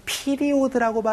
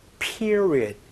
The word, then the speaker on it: period: 피리오드라고 발음하지 않고요. period, period 이렇게 발음하니까 요거 주의해 주시면서 수습기간이 이제 끝났어요.